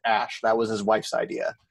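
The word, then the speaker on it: Æ: That's pronounced Ash. – Is it really? Is that is that his name? It's Ash.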